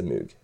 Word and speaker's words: Moog: Nej, det heter mug. Det heter mug. – Det låter att si ting med norsk uttale då. – Ja, men det låter att säga ting som det egentligen ska uttalas. – Men han duden. Men han duden heter väl liksom Bob Moog eller sånt liksom. Ja. Det. – Ja. – Jag vet inte. – Han borde skifta namn till Mooge. Hvis han ska insistera på att det ska uttalas Moog. – Ja, sånn ja. – Da blir det M-O-U-E.